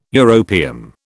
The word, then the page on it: europium: British English